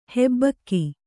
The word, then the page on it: ಹಬ್ಬುಗೆ: habbuge